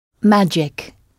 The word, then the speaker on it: magic: Magic. – Magic, oui. Avec le G prononcé J, magic. Et donc, comment diriez-vous, c'était un moment magique.